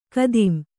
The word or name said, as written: ಖತಂ